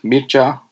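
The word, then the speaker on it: Mircea: My name is pronounced Mircha, like Mir and Cha, like in, you know, the dance, Cha-Cha.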